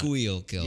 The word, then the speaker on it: Schuylkill: Schuylkill.